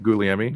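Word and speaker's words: Gugliemi: It's Guglielmi. His Guglielmi. Name is pronounced Guglielmi.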